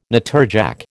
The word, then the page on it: natterjack: American English